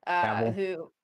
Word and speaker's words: Cavill: Oh my God, I always say his name wrong. Henry Cavill. Cable. Cavill. Cavill. Thank you.